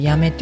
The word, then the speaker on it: 八女: 時から私もあのもう夏休みになるともう毎年のように行ってました、えー、母の出身はまあ福岡県八女市というところなんですね今はねあの八女というと八音八に女って書いて八女って読む